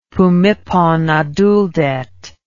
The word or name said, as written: phumiphonaduldet